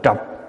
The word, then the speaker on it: trược: Chữ trược là dơ, người Bắc đọc là trọc, nên mới tạo không biết bao nhiêu nghiệp trói buộc.